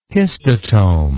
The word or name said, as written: histotome